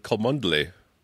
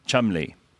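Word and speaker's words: Cholmondeley: That's C-H-O-L-M-O-N-D-E-L-E-Y. – I would go with Kolmondeley. – Kolmondeley – Kolmondeley? – No, Chumley.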